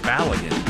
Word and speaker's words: Balogun: See, I got his name right today. It's Balogun.